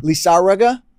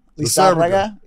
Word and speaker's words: Lizárraga: Lizárraga. Lizárraga. – Yeah. Yes, you have to say it that way, man, because if I try to roll the R's like you, I'm going to mess it up.